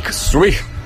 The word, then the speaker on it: Zweig: Zweig.